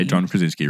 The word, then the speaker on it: John Krasinski: John Krasinski. John Krasinski. – Yes, that is how you say John Krasinski.